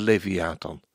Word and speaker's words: Leviathan: Want al kunnen wij mensen de Leviathan niet overwinnen, Jesaja profeteert de ondergang van het beest. Op die dag zal de Heere vergelding doen. Ik lees dit vers nog eens, want de klemtoon lag verkeerd.